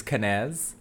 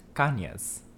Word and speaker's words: Cañez: So I grew up with the white way of saying my name, which is Cañez, which I guess my dad adopted when he went into the military. And then it wasn't until I was like older that I found out it's correctly pronounced Cañez.